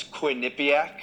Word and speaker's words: Quinnipiac: And when you graduated from Michigan, you initially you started out with a Quinnipiac, right? Am I pronouncing that right? – It's Quinnipiac.